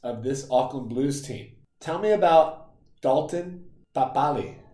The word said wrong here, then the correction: Papali'i: So Dalton Papali'i? – Papali'i.